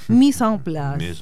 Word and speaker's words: mise en place: Le pregunté a Sergio Manrique, que es nuestro colaborador de San Juan, profesor de francés, le dije, por favor, decime cómo se dice. Mise en Place.